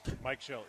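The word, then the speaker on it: Mike Shildt: Mike Schilt, yeah.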